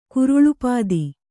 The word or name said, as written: ಕುರುಳುಪಾದಿ